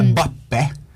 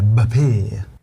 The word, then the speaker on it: Mbappé: Om man säger Mbappe så kommer ju någon att säga det heter Mbappé.